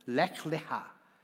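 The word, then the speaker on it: lech leja (לך לך): Está compuesta por dos palabras en hebreo, que son leja, que, básicamente se pronuncia como lech leja.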